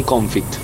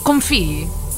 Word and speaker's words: confit: Un amante di pomodorini confit. – Ballata. – Non si diceva con- confì? – Poesie.